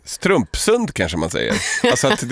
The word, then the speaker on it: Strömsund: Strömsund uttalas med betoning på första stavelsen. Strömsund. – Strömsund.